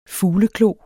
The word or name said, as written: Ornithopus